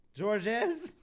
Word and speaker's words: Georges: The S is silent. – Georges.